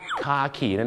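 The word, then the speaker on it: khaki: แต่ภาษาอังกฤษเขาจะออกเสียงว่าคาขีนั่นเองครับใช่คล้ายๆกับคาขีนั่นแหละฮะน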